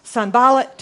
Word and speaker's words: Sanballat: So the enemies, you're wondering how to pronounce those names, right? Sanballat, Tobiah, Geshem. Those are how to pronounce the three names.